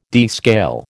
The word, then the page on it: descale: American English